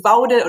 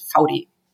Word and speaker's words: Vaude: um, people say also Wood and Woude and Faude, but the correct pronunciation is Faudi.